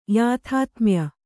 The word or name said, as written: ಯಾಥಾತ್ಮ್ಯ